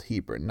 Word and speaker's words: Hebron: Now, before you laugh at my pronunciation, there's a town called Hebron not far from here, and it's spelled the same in everything, so I know how to pronounce it, since Midwestern settlements named after the Old World always preserve the original pronunciation, even if the Old World strays.